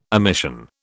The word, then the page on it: omission: British English